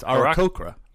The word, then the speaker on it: Aarakocra: Close. It's Aarakocra.